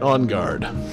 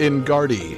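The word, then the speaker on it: Enguarde: Is it Ingard or is it Ongard? – Ongard. – I always assumed Ongard. – Probably Ongard. – I always see as a kid. I always pronounced it Ingardy because there was an E at the end, and I was stupid.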